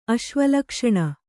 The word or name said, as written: ಅಶ್ವಲಕ್ಷಣ